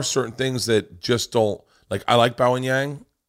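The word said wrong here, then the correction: Bowen Yang: Am I saying his name right? – It's Bowen. But yeah, I'm not writing for every single person on Saturday Night Live.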